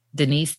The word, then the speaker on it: Denise: Well, the easiest way is to find me on my website, which is TaVona Denise. And my name is spelled TaVona, T-A-V as in Victor, O-N-A, Denise, D-E-N-I-S-E.